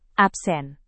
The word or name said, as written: absen